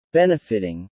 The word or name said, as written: benefiting